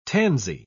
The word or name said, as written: tansy